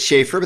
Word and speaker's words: Schaffer: It's Schaefer, but that's okay.